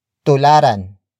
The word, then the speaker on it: tularan: Tularan natin ang mga bayani. Tularan natin ang mga bayani. The first word is tularan. Tularan. Tularan. Tularan is spelled as T-U-L-A-R-A-N.